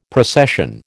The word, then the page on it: procession: American English